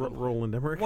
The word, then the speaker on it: Roland Emmerich: Either Ronald Emmerich or Michael Bay needs to direct. – Roland Emmerich.